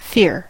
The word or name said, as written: fear